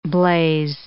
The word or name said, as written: blaze